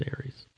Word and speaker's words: Eretz: That Ares Israel was spelled E-R-E-T-Z, but it's pronounced Ares. That's actual Hebrew.